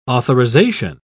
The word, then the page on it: authorisation: British English